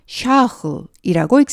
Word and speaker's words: Charles: 따라서 프렌치로 CHARLES는 샤흐이라고 읽습니다.